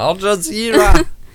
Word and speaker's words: Al Jazeera: One website that I use for news a lot is Al Jazeera. I don't know how to pronounce it. – Al Jazeera!